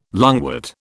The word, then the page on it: lungwort: British English